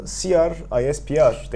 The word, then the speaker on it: CRISPR: Amerika'da CR, ISPR tekniği. CRISPR diye okunuyor kısaca.